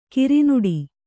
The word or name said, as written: ಕರ್ನಾಟ